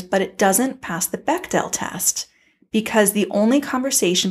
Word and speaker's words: Bechdel: I pronounced it Bechdel test for most of my life. And last week I found out it's pronounced Bechdel test.